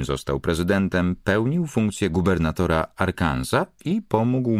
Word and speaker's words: Arkansas: Clinton urodził się w najbiedniejszym i najprymitywniejszym wówczas stanie USA, Arkansas. Co ciekawe, jego nazwa wywodzi się z języka francuskiego, stąd nieme S i inna wymowa niż w przypadku Kansas.